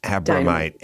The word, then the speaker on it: Abromeit: It rhymes with dynamite, Abromeit. – Abromeit, Abromeit, Susie Abromeit.